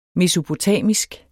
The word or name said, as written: mesopotamisk